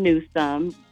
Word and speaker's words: Newsome: At I am Kim Newsome, and my last name is spelled N-E-W-S-O-M-E.